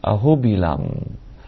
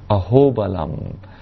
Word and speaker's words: Ahobilam: Once, 10 years ago, I was so fortunate to visit the temples of Nrisimhadev in Ahobilam, or you can also pronounce it Ahobalam. When you pronounce it Ahobalam, it means, oh, what great strength.